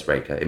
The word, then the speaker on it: Reykja: Well, tell me a mini story of Reykja. Am I pronouncing it right? – Yeah, that's good. Lots of people say like Reykja or, but yeah, it's Reykja. It means smoke in Icelandic.